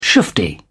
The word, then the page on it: shufti: British English